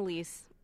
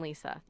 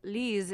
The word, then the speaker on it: Lise: They call me Lise and Lisa. – But Lee, so what did you say, Lise? – Lise is like the French pronunciation. – Lise. – And then like Norwegian, which is my background, is Lisa.